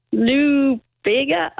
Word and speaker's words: Lou Bega: Lou Bega, I cannot pronounce it. – I think you've pronounced it correctly. Lou Bega Mambo Number 5.